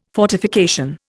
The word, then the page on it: fortification: Indian